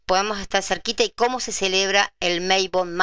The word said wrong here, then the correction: Mabon: Mabon. Si lo buscan por internet, buscan Mabon, porque se escribe así, Mabon. M-A, B larga, O-N, ¿sí? Bueno,